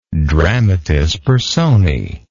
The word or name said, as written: dramatis personae